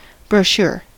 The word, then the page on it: brochure: American English